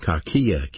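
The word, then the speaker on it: kakia (κακία): Malice is a Greek word pronounced kakia, K-A-K-I-A, kakia.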